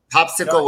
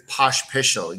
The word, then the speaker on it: Pospisil: Popsicle works, but it's Pospisil. You got to pretend there's a H after every S.